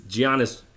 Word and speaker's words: Giannis: That's a really long-ass preamble, but the focus of this is Giannis. There's the G is silent.